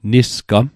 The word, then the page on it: Nisgam: Mi'gmaq